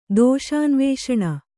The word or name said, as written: ದೋಷಾನ್ವೇಷಣ